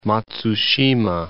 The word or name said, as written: Matsushima